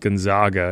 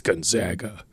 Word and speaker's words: Gonzaga: I mean, like, I can understand, like, Gonzaga. Like, it's Gonzaga. It's Gonzaga. And, like, the coach, Kelvin Sampson actually got mad, the coach of Houston, because someone said Gonzaga. And he's like, it's Gonzaga.